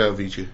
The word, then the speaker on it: Avicii: How do you pronounce it? Avicii? – Oh, yeah. Yeah, Avicii. Yeah.